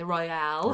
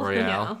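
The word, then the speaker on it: Isle Royale: Isle Royal is pronounced like royal. Nothing fancy is going on with that E. There's an E. Royale. – Royale. Yeah. Royale with cheese.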